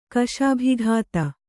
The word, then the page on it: ಕಸುಬುಗತ್ತಿ: Kannada